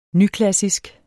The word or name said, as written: nyklassisk